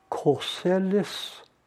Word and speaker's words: Corsellis: Corsellis rhymes with trellis, hence fence.